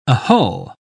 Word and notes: ahull: British English

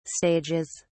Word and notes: stages: British English

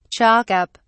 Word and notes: chalk up: British English

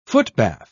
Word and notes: footbath: British English

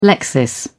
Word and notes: lexis: British English

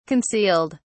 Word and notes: concealed: British English

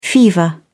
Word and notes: fever: British English

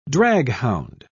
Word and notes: draghound: British English

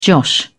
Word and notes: josh: British English

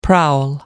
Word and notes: prowl: American English

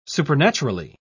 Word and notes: supernaturally: British English

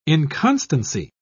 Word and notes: inconstancy: British English

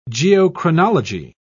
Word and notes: geochronology: British English

